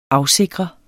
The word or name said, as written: AFSIKRE